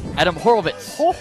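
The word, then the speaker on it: Adam Horovitz: Adam Horowitz.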